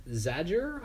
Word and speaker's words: Zajur: It stars Zoe Margaret Coletti as Stella Nichols, Michael Garza as Ramon Morales, Gabriel Rush as Augie Hildebrandt, and Austin Zajur, I think is how you say it. It's Z-A-J-U-R, as Chuck Steinberg. – Yeah, I'd say Zajur is.